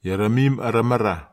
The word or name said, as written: ieramin aramara